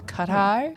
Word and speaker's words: Qatar: So anyway, it's pronounced Qatar。这一次的 Qatar 有一点 Qatar 不对，这一次在 Qatar 办的世足有一点不一样。你知道哪里不一样吗？ – 很热吗？ – 谁很热？ – 很有钱，是 Qatar 很热、嗯，很有钱。